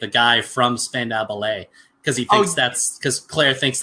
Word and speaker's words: Spandau Ballet: Is it Spandau Ballet? – Spandau Ballet.